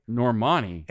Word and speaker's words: Normani: Normani. – N-O-R-M-A-N-I, confirming her relationship with NFL top wide receiver DK Metcalf.